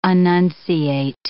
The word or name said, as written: annunciate